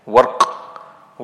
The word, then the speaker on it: ورق: It can also be read warq with a sukun on the ra for takhfif, for ease of pronunciation. The word is warq, and it means silver.